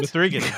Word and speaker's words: M3GAN: She never actually pronounces the three in her name, Jeff. Mm-hmm. Yeah. – You mean M3GAN? Doesn't? – M3GAN.